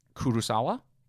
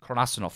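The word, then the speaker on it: Karasuno: Kurosawa? – Kurasuno.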